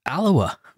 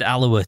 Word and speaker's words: Alloa: He said they bumped into him, and Spears said to him, I heard that the other week you went to somewhere like Alloa. You were at Alloa to report. And Leckie was saying, except he said Alloa to rhyme with gonorrhea.